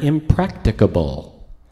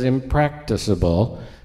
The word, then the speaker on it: impracticable: and it's the word impracticable, which I didn't even know how to pronounce it. I thought it was impracticable until I looked up the pronunciation guide.